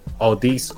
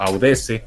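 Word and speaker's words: Audeze: O para la banda, así que lo lee como se escribe, es Audis o Audese, ¿no?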